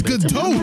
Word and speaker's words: Gadot: Yeah, nigga, it's Gadot.